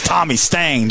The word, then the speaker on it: Tommy Stang: This is Tommy Stang joining us here.